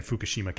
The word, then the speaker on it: Fukushima: And Fukushima Castle. – Yes. Perfect pronunciation.